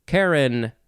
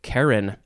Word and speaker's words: Charon: I think I shall pick Karen. I think that's how it's pronounced. Karen, C-H-A-R-O-N,